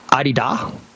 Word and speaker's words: Adidas: I was learning the correct way to say it is Adidas. Adidas? Like hard D's? I'm like, really? Americans are never going to go for that. So we'll just call them Adidas.